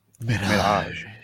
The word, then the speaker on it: Mirage: Also got the Assassin's Creed Mirage Platinum. Nice. – You didn't say Mirage properly. Eternites? – What? – You didn't say Mirage properly. – Mirage. – That's better. I feel better.